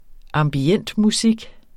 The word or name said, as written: ambientmusik